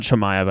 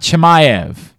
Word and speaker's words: Chimaev: Nate Diaz against, uh, tell me how to pronounce it's Hamzat Hamayev. Hamzat Hamayev. Hamzat Chimaev, I guess. I think everyone pronounces the CH. Hamzat Chimaev. Bingo. Let's make sure we have that right. It's Chimaev.